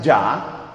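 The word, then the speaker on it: ja: Ese nombre Ouija proviene de la palabra sí, dicha en francés, que es oui, y de la palabra sí, dicha en alemán, que es J-A y se pronuncia ya.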